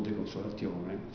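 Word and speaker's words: De consolatione: Una breve delucidazione sull'opera De Consolazione, o De Consolazione, secondo la pronuncia classica,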